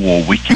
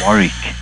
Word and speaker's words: Warwick: It's not like Dionne Warwick, it's Warwick.